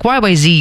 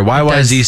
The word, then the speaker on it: YYZ: I just said Z. That's funny. – It sounds better. YYZ sounds better than YYZ. – Yeah. And it rhymes with C, but yeah.